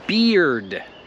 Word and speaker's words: beard: I broda to jest beard. Beard. Przez to, że jak mamy ea albo ee, to się wymawia i. I to nie jest krótkie i, tak jak polskie i. To jest długie i. Beard.